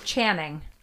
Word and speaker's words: Channing: It's Channing.